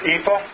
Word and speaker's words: EAFE: And that's how it's pronounced, EAFE. EAFE. Figure that one out.